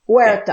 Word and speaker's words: Huerta: Huerta.